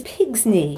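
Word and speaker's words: pigsney: is pig's knee. – Pig's knee, which is pig's knee. – Really weird. – It's spelt P-I-G-S-N-E-Y, P-I-G-S-N-Y-E, P-I-G-S-N-I-E.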